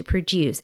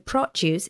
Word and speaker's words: produce: In this case, to produce, it's a verb. So we have the stress in the second syllable, to produce. And produce is noun, so the stress falls on the first syllable.